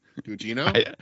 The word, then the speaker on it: Gugino: Guggenos. Guggenos. Take a run at it. – Gugino? – That's better.